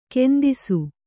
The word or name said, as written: ಕಂದಿಸು